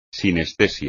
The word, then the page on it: sinestesia: espagnol